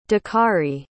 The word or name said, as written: dakari